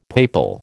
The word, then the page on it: papal: American English